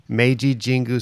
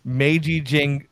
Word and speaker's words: Meiji Jingu: Meiji Jingu Stadium. Babe Ruth played there. – Meiji Jingu Stadium.